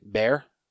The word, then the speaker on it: Bhaer: I don't know if I just pronounced his name right. Bear? Wasn't it Bear? B H A E R? – Yeah, sure.